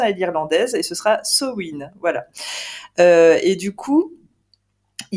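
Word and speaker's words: Samhain: J'ai entendu que c'était Sowin, mais bon, on sait que techniquement, les gens ne vont pas le prononcer comme ça parce qu'on n'est pas irlandais.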